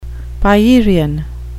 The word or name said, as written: pierian